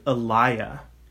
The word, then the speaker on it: Eloi: And according to uh, theologian Bob Utley, that would have probably pr- been pronounced closer to Elia.